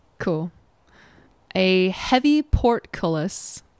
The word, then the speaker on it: portcullis: Portcullis. Portcullis. – So, and the emphasis is on the cull.